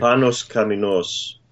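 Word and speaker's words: Panos Kammenos: Could you get your wife and see if I pronounce that right? – Panos Kamenos. – Okay.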